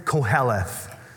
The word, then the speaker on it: Kohelet: who is Koheleth. So Kohelet is more how you'd be pronouncing it.